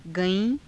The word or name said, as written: gaii~